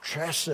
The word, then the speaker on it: Hesed: The way we would bring that over into English would be H-E-S-E-D. Hesed. Sometimes because of Hebrew being a guttural language and you almost have to sound like you're spitting when you say it, they'll put C-H. Hesed. It's kind of, you kind of got to have that guttural sound to say it right.